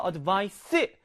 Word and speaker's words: advice: 발음도 차이 있어요. advice, advise입니다. advise.